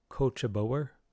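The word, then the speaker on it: Cóiste Bodhar: It looks from how it's spelled, Cuist Budhar, but it's apparently pronounced Cochaboer.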